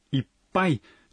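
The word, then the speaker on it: Иппай (いっぱい): Произношение «иппай» непростое. – «Иппай» содержит удваиваемый согласный звук. Когда вы произносите это слово, нужно на секунду задержать дыхание.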